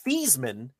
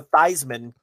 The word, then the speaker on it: Theismann: Did you know Joe Theismann, Rich, pronounced his name Theismann until he got to Notre Dame, and he changed it to Theismann because it rhymed with Heisman, and he thought it would increase his chances of winning the award.